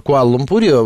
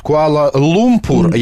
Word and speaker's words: Куала-Лумпур: Я все сижу и мучаюсь, потому что Лера говорит Куала-Лумпур, я говорю Куала-Лумпур. А оказывается, что сами малайзийцы называют ударение, ставят Куала-Лумпур. А мы в русском языке можно ставить Куала-Лумпур.